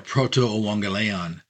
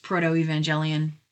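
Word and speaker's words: Protoevangelion: Um, and that's called the uh, Proto Euangelion and that's the, the called the proto or pre uh, gospel. – For lay people, you may have heard it pronounced proto evangelion or the proto evangelion.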